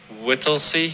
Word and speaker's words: Whittlesey: Is it, how do you say Whittlesey? – Whittlesey.